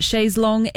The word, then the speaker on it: chaise longue: Wet Leg and Chaise Longue. I will admit I never actually knew how to pronounce that correctly until this song came out. It's always confused me, but Chaise Longue it is, and I now know how it is pronounced.